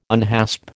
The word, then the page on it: unhasp: American English